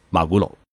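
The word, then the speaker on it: マグロ: 金枪鱼在日文中呢念作马古罗，英文念作 tuna，所以。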